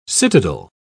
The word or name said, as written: citadel